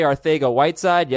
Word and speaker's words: Arcega-Whiteside: Arcega-Whiteside. Yes, that is how you pronounce it.